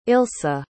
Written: ilsa

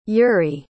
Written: yury

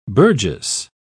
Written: Burgess

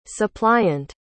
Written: supplyant

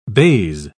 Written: baize

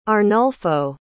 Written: Arnulfo

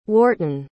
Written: wharton

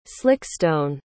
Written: slickstone

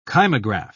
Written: kymograph

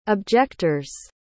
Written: objector's

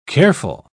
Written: careful

